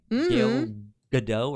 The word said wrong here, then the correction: Gal Gadot: or however you say her name. – Well, not Gail. – It's Gal Gadot. – Yeah, I think it's Gal Gadot. – Gal Gadot. I'm. – I think everybody thinks place. – It's supposed to be a silent T, but it is in fact a loud T. – It's a loud T. It's a hard T.